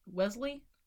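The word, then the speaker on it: Wellesley: Wesley.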